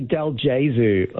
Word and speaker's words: Dalgazu: Slightly incorrect, but it's all good. It's actually Dalgazu, like Yezu, like Jesus, yeah.